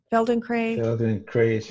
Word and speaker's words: Feldenkrais: And I always have trouble pronouncing this neck. Feldenkrais? – Feldenkrais, yeah. I don't know. – I've definitely heard of it. It's spelled F like Frank, Eldenkrais.